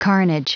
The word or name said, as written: carnage